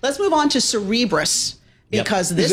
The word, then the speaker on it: Cerebras: Like Cerebral. Yes. So Cerebras shares soaring.